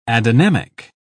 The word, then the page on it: adynamic: British English